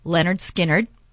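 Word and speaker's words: Lynyrd Skynyrd: It was called Pronounced Leonard Skinner.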